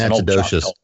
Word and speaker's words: Natchitoches: It's a little town, and I hope I'm pronouncing that correctly. It's Natchitoches. – thank you.